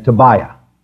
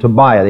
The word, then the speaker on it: Tobiah: Sanballat and Tobiah. Sanballat and Tobiah. The accent is on the middle of those, the middle syllable there.